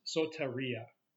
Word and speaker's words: soteria: Now, the Greek word that's translated salvation is pronounced soteria.